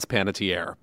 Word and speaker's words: Panettiere: That woman sounded way too smart not to know how to pronounce Panettiere, but I'll allow it.